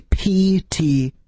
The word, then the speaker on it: Ptolus: It's actually P-T-O-L-U-S. – Yeah, there's a silent P in front of that if you're looking for it on Google.